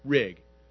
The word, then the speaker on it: Rig: Rig. You know, it didn't take kids long to realize what rhymes with Rig is pig.